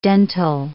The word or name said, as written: dental